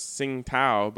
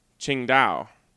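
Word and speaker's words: Tsingtao: It was usually a I thought it was Sing Tao, but he says it's pronounced Ching Dao.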